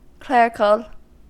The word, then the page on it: clerical: American English